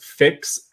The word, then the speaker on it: Phyx: Uh, this is from Better with in am I pronouncing it correct? Phyx F or P H Y X. Is that is that correct? Yeah. Okay.